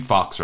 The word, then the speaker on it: Faux: Is it Zeke Fox, right? It's spelled like foe, but it's Fox.